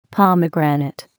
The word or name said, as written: pomegranate